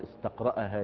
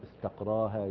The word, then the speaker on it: استقراها: ونبه يعني المؤلف إلى خطأ لغوي، بعض الناس يقول استقرأ الفقيه الأدلة، فقال هذا خطأ، يعني لا تنطق بالهمزة، وإنما يقال استقرأ الأدلة يستقريها، ولا يقال استقرأها يستقرئها، وإنما يقال استقراها يستقريها، هكذا.